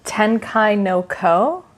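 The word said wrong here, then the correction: Tenki no Ko (天気の子): Yeah, Tenki no Ko. That's actually the name of Weathering With You in Japanese, and it literally translates to weather children, like the children of the weather.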